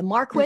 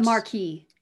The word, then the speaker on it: Marquis: The marquis, mm-hmm. or the a marquis. – It looks like, I always thought it was pronounced Marquis, but apparently it's pronounced Marquis. – What? – Marquis. M-A-R-Q-U-I-S.